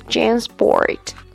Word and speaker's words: Jansport: And the next one is Jansport。我们知道 Jansport 是很受年轻人欢迎的后背包品牌。这个词的发音可以拆成两个部分，Jan 和 Sport。Jan 的 A 发蝴蝶音，A sport 就是运动的意思。